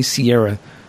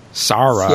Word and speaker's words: Ciara: They don't say Ciara. They say, like, Sarah.